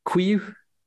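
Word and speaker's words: Couilloud: So he's pronounced Quiu. – It's certainly how it's... Quiu. – Quiu, Baptiste Quiu, which I think it should be Quilude, yeah. Based on how it's spelt, but Quiu.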